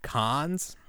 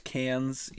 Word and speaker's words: Cannes: Did you see that they debuted Indiana Jones at Cannes or Cannes? How do you say that? – I've always heard it pronounced cans.